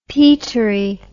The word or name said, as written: peatery